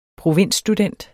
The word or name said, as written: provinsstudent